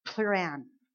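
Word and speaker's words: pleuran (πλευράν): It is, I believe, in the Greek, pronounced pleuran.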